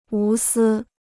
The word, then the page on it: 无私: wú sī